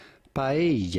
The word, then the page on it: Paella: Spanish